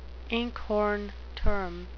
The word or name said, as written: inkhorn term